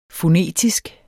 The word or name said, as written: fonetisk